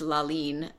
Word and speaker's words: Lalin: And she, when she was writing this work for us, so the work is actually pronounced Lalin, and that's how she's phonetically written it out in the score,